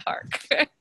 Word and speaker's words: Harck: It's spelled Tambra, and my last name's Harck, Harck. And you get extra special bonus points if you pronounce it beautifully like Renee did today, which is Tambra Harck.